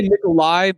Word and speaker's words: Nikolaj: Is it Nikolaj? – I would say Nikolai because I think he's from Denmark and most, Eastern Northern European countries have that J to Y sound. – Fair enough. So Nikolai Coster-Waldau, I actually liked his character.